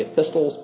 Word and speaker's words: epistles: I used to have a friend in Germantown who always pronounced the T. He always spoke of the epistles by the apostles. And he was not the sort of man you corrected easily. So you just let it go because he was probably convinced that the T was there, was to be pronounced.